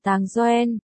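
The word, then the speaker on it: Táng Yuǎn: Đường Viễn phiên âm là Tàng Doen, phát âm gần giống từ bánh trôi là Tăng Ghiêu Hoán.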